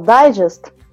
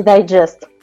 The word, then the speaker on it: Digest: Ну, начать нужно с того, наверное, что это называется Drupal Digest, а не Digest.